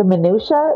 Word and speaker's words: minutia: The minutia, is that what it said? Minutia, that's how you pronounce it.